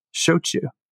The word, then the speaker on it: shochu: two common English verbs, show and chew. As if you're speaking to a child, show me how you chew your food, shochu.